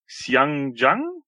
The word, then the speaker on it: Xiang Zhang: Und zwar für mich persönliches Highlight ist der Beast Wars Megatron, der neue von Xiang Zhang oder so ähnlich. – Ja, genau. – Wie man das auch immer ausspricht, ja. – Als gebürtiger Chinese, ja, so spricht man das aus.